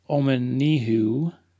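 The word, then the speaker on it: Omenihu: So I want to get his name pronounced correctly here. It's Charles Omanihu. Omanihu is a defensive lineman.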